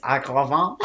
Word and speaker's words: Agravain: The other thing is that they all pronounce the names the French way. So Lancelot is Lancelot. Guinevere is Guinevere, which is fucked up. Agravain is Agravain.